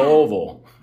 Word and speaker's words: Louisville: Yeah, the locals say you got to put rocks in your mouth to say the name properly. Yeah. Louisville.